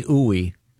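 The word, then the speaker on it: Ooi: Dr. Stephanie Ooey, there's a pronouncer here, and it says it rhymes with gooey.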